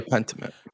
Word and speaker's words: Pentiment: How do I pronounce this? Pentiment Form? Yeah. Is that what it's called? Or is it just Pentiment? Oh, it's just Pentiment. – Yeah, Pentiment.